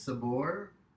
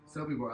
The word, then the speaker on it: Sobibor: He goes rogue, then goes to Russia with resistance, with his resistance love, and finally to, how do you say this, Sobibor. Sobibor. I like when that came up, Ashes, that you were like, oh, good, this is the one.